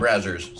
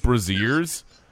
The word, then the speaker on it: Brazzers: Brazzers. – Brazzers. Because I would think like Braziers.